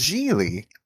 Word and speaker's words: Gigli: You can't possibly believe that that's how that's pronounced, right? You're doing a bit, right? What do you think it is? It's Geely. – And uh, Geely.